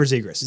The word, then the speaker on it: Zegras: Am I saying that right? Is it Zegras? – Trevor Zegras. – Zegras.